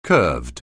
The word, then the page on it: curved: anglais